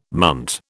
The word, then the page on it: munt: British English